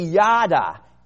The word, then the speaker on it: yadah: And the Hebrew word here is phonetically yada, yada, Y-A-D-A-H.